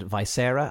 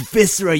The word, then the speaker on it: Viscera: I was listening to a review of a game called Viscera Cleanup Detail, and the person who reviewed it said Visera, and I'm like, they don't come across that word very often, so of course they mispronounced it, but everyone in the comments is like, it's Viscera, you fucking idiot.